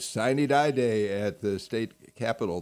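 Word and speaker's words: sine die: Murphy was speaker, but you said it's pronounced Signy Die, y'all.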